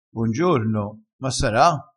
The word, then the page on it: bongiorno: dialettale